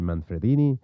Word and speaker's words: Manfredini: But he did Watchers from 1988, Below the Belt from 1980, and then we have Harry Manfredini, and again, just because it's Italian as hell, I'm going to go ahead and say it like that, Manfredini, whatever the hell.